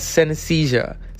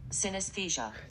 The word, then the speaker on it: synesthesia: Justin Chancellor, bassist for Tool, has synesthesia, seeing colors when hearing music. That's interesting, isn't it? Yeah. Let's make sure we say it right, because I'm all about pronunciation. – Synesthesia. – Synesthesia.